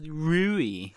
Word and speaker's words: Rui: We are only going to go by the official Japanese dub, and it's pronounced Rui.